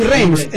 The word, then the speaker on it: Rhames: En este caso va a ser una de acción con Seagal, con Rames, que es el negro de Misión Imposible, para que te ubiques. – Ah, Ving Rhames, Marcelo Wallace de... – Sí, perdón por mi pronunciación.